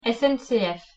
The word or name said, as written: SNCF